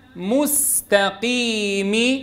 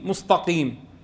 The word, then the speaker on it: مستقيم: إذن ورقق السين أن جاءت، خصوصا في الكلمات التي يقع فيها الخلل نتيجة التجاور، وضرب لها أمثلة فقال وسينة مستقيم. البعض بينطقها نتيجة يعني مجيئها في هذا اللفظ فيقول مستقيم مستقيم، بفخم السين والتاء ونتيجة التجاور مع القاف.